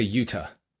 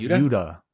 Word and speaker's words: Utah: I say Utah. – Utah. – Utah. What's a Yuta? Soft T? What's a soft T? – Well, like Yuta. Like pronouncing like a D? Like a D. – Almost. Oh, Utah. Yeah. I might say it too fast and revert to D.